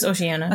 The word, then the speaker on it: Oceania: Eris was the sister, or is, whatever, the sister of the current queen of, how do they pronounce it? Oceania? – It's Oceania.